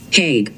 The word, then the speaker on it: Haig: Haig.